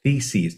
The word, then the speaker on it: theses: You said theses, T-H-E-S-E-S, not anything that rhymes with that.